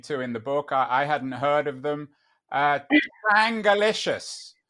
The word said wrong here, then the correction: Chongalicious: Chongalicious. The Chonga girls. – I'm going to pronounce it right. Chongalicious, not yeah. Changa, we spelt it wrong, with an O, Chongalicious.